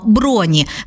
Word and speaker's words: броні: Відповідаємо, правильно, броні. Наголос зберігається на тому самому складі, що й у вихідній формі.